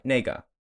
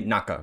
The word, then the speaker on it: nega: So nega. – Okay. – Or I used to say naka because I was just so wrong. But that's not how you pronounce it at all. It's N-E-G-A.